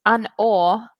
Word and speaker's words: An Oa: So it's An Awe. That's how it's pronounced.